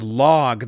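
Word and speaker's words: Logh: The show is called A Constant Grin, and the song I just played for you was by the band Log. That song was called A Habian, and that's Log spelled with a silent H at the end.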